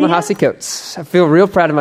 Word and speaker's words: Ta-Nehisi Coates: I love watching you tiptoe around on eggshells when it's like, what was the guy who wrote, what was Ta-Nehisi Coates? – What's his name? – Ta-Nehisi Coates. I got it now. Ta-Nehisi. – Ta-Nehisi.